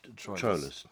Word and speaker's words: Troilus: Is it Troilus or Troilus? – It's two syllable there. – Troilus.